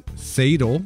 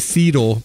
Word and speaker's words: Seidel: Is how I'm going to pronounce that. I really want to say Seidel, but I think that would be the Swiss pronunciation. So okay. – I'll stick with the German and be with the more people, I guess.